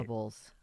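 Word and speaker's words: Doorables: You know, then, it's spelled Dorables, as in each day you open a little door. D-O-O-R. – Right. – Dorables. I was thinking like Dora.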